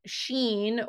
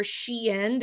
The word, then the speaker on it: Shein: And currently right now, this fast fashion retailer called Shein, or Shein, depending on how you say it, S-H-E-I-N,